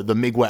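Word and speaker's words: Mgła: It's Mgla, but the L's got some kind of weird accent, which I believe in Polish is pronounced like a W.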